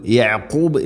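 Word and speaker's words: യഅഖൂബ് (يعقوب): യഅഖൂബ് എന്നതിൽ ഐൻ തൊണ്ടയുടെ മധ്യഭാഗം വയഅഖൂബ് എന്നല്ല, വയഅഖൂബ്. ഐനും ഖാഫും ഉച്ചാരണം പ്രത്യേകം ശ്രദ്ധിക്കണം.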